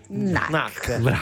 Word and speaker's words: Knack: Come si legge? Knack. – Brava.